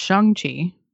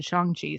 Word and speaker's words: Shang-Chi: Shang-Chi, is pronounced Shang-Chi. By the way, because I noticed that. I've always been saying Shang-Chi, but Feige said Shang-Chi, so I'm like, okay, thank you for saying that, because now I know.